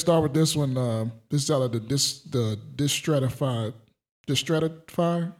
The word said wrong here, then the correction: Distractify: Is it distractify? – Distractify.